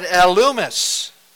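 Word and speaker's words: Elymas: But Elumus, and that's how you pronounce his name.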